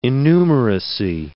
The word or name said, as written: innumeracy